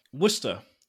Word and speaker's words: Worcestershire: Worcestershire? – Worcestershire. Worcestershire. – Worcester.